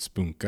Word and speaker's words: Spunk: And it is Norwegians do pronounce it Spunk. I found out last night. Someone had corrected me, like, no, no, it's Spunk. But and and before anyone gets squeamish, I mean, it's it's um of course that you're spunk, you're spunky.